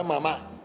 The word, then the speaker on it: mamá: Cuando tú entras a detallar y te, alguien te dice la palabra mamá, entonces tú te das cuenta que es una palabra aguda porque la, tilde la lleva la última sílaba.